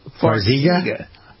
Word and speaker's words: Farxiga: Farxiga? – Yes, is how it's pronounced.